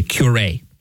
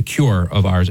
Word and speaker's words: curé: which in French would be the curé. See, it's spelled like the word cure, C-U-R-E.